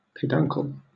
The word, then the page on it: peduncle: British English